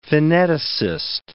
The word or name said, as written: pheneticist